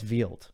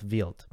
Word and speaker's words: Wild: Or as he says on the ATP, I don't know if this is how people are going to say his name. He says Wild. So Thiago Seaboth Wild.